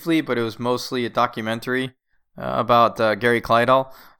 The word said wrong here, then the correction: Kildall: So Kildahl? – Kildahl, yes, sorry.